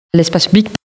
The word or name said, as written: L'espace public